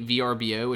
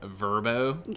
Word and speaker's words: Vrbo: Now, this could also be VRBO, which I have recently learned is called Vrbo. – Get it right. – Because I saw a commercial from Vrbo, and I laughed out loud when I heard them pronounce it Vrbo, even though I've been saying VRBO, but I'm like, okay.